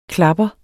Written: klapper

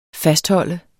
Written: fastholder

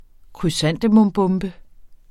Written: krysantemumbombe

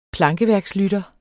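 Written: PLANKEVÆRKSLYTTER

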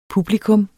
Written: PUBLIKUMS

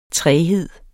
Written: træghed

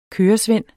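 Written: køresvend

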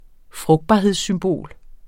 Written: frugtbarhedssymbol

